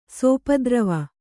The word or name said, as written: ಸಪ್ತೋರ್ವಿ